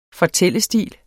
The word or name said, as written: fortællestil